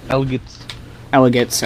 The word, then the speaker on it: McElligott's: If I Ran a Zoo, Mick Elliott's Pool. I don't know if that, or Elligott's Pool, McElligott's Pool. I don't know how you pronounce that, actually. – Elligott's, okay.